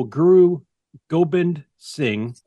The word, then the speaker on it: Guru Gobind Singh: One individual, Guru Gobind Singh, if I pronounce that correctly.